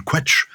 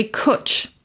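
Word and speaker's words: cwtch: I like the way he spells Charlie, C-H-A-R-L-E-Y. I like his sign-off, ever yours. That has an old-school charm about it. I would have pronounced the Welsh word quetch, because it's spelled like that, but is it not pronounced that way? – No, it's definitely cutch. – Cutch. – To rhyme with butch. Butch, essentially.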